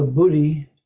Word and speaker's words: buddhi: There's the buddhi, however they pronounce it, with a little bit of H at the sound of it.